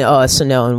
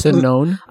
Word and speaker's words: Sinon: QUnit would be my second favorite before moving on to Mocha or Chai or even Sinone, which is for doing more stubs and mocks. – How do you spell that? – Sinon. I always mispronounce it. Some people say Sinone, some people say Sinon.